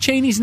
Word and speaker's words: Cheney: Number one, we've been pronouncing Dick Cheney's name wrong all these years. The Cheney family's preferred pronunciation rhymes with genie, not zany.